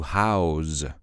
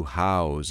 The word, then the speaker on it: house: It is, if we change the pronunciation. To house. To house is a verb.